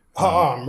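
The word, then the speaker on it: Ahad Ha'am: I'll butcher his name and you can correct me, Ahad Haham. – Haham, right.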